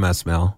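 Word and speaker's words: Esmail: How do you pronounce it? – Sam Ismail. – Ismail. Okay. – S and then the mail.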